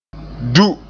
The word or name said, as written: du